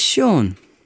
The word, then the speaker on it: Sean: My name is Sean. – How do you spell that? – S-E-A-N.